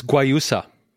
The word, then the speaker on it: guayusa: It's caffeinated guayusa tea. Am I pronouncing that right? – It's guayusa. – Guayusa. Thank you.